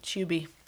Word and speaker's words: Tubi: We don't pronounce tube like you do. We say it like chew with a B at the end. – Yeah, Tubi.